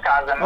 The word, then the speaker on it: Chasm: Sì, Chasm, si pronuncia Chasm.